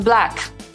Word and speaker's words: black: A co w takim razie ze słowem black, czyli czarny? No, wymawia się właściwie tak samo jak back, więc jest ta sama samogłoska w środku.